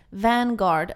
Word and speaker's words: Vanguard: Vango, nu kan jag inte ens uttala det, Vanguard Award, som också kallas för Lifetime Achievement Award, till Kanye West.